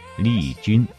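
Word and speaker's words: 丽筠: 而实际上，也许是有边读边，无边读中间的惯性使然，当时一般人都把“ 云” 字读成“ 君” 字的音，丽君、丽君的这么叫着，被叫惯了，就连邓妈妈也都喊她丽君。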